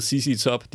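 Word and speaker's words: ZZ Top: Jo, men, men, de hedder CC, men de hedder CC Top, de hedder ikke Set Top.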